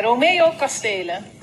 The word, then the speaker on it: Romeo Kastelen: Ehm, was het nou, is het nou Romeo Kasselen of Romeo Kastelen? Zullen we even luisteren? Ja, dat is goed. – Dan komen we bij Romeo Kastelen.